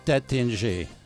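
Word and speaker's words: Taittinger: And being French, I would pronounce the word Tétinger.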